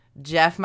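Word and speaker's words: Gef: Jeff might be my new favorite cryptid. – I was going to call him Geff because it's G-E-F, and then I read the story and it's pronounced Jeff, and I was like, Jeff the Talking Mongoose is the greatest thing.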